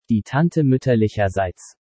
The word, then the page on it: Die Tante muetterlicherseits: German